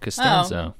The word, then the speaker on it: Costanzo: And I actually learned that he pronounces his name Costanzo. – Oh, Costanzo. Oh. Okay. – Anthony Roth Costanzo, yes.